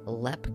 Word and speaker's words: Leap: So the Lepp Castle, Lindsay, is spelled like leap, but so if you're trying to research this online, you're wanting to look it up, you're going to spell it like leap, L-E-A-P, but it is pronounced Lepp Castle, which kind of confused me a little bit at first.